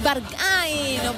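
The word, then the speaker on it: Berghain: La milésima intacha es la de Rosalía, Barkay. – Es Barkay, no como cada uno lo quiera pronunciar, pero efectivamente es esta canción súper conocida, lo decíamos, actual y que suena mucho.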